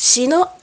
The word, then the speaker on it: Shino: Mi nombre es Shino Onaga. Shino se escribe S-H-I-N-O.